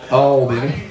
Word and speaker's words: Albany: So this Friday night we head over to Albany, Georgia. Got to say it correctly. You have to get that, turn that LB into a W. Albany.